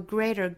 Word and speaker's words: greater: To do a greater good. Greater 中间的的发音发弹舌音，我们周一也出现这个音了哈。 Greater good 类似于我们周一讲到的 matter 的发法，都是舌尖在硬腭一弹而过，发 matter greater good。